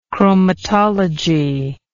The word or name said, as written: chromatologist